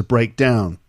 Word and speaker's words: breakdown: So when it's a noun, the first syllable is stressed, breakdown. And when it's a verb, the second part is stressed, to break down.